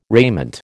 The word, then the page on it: raiment: American English